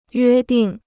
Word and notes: 约定: yuē dìng

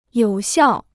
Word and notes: 有效: yǒu xiào

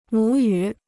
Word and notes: 母语: mǔ yǔ

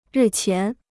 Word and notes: 日前: rì qián

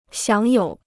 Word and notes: 享有: xiǎng yǒu

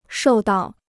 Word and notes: 受到: shòu dào

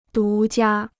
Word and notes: 独家: dú jiā

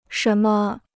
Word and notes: 什么: shén me

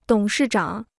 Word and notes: 董事长: dǒng shì zhǎng

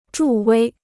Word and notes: 助威: zhù wēi

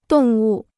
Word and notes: 动物: dòng wù

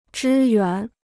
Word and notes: 支援: zhī yuán

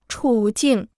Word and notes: 处境: chǔ jìng

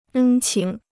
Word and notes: 恩情: ēn qíng

